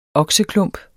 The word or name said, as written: okseklump